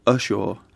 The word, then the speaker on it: Ushaw: It's called Usher? – Ushaw. – Ushaw.